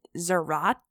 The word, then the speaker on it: Zarate: So 18-year-old Ruben Zarate. It's honestly, it's spelled like karate, but with a Z.